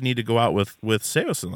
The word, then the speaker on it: Saosin: Like, no, it's not Saosin, it's Saosin. And, like, everyone had, like, these little things with, like, it was, like, this weird ownership. Like, no, you're completely wrong. That's not how it's pronounced.